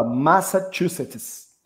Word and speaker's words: Massachusetts: Massachusetts.